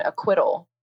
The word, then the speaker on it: acquittal: You know, and I remember I was in eighth grade and I won the spelling bee on acquittal. And I'm still so proud of that because it has a silent C in there and I nailed it under pressure and I took home the trophy.